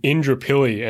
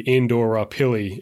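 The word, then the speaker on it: Indooroopilly: You know, we've got Indrapilly, and the joke for that one used to be Indora Pilly is how you'd, you know, phonetically sound it out.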